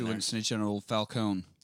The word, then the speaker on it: Falcone: Yeah, well, when I was in the old country, that's how we pronounce it. – When they go for a little bit of the gabagool, maybe they put that emphasis on there. But here at the courthouse, he's just Falcone.